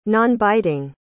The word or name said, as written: nonbiting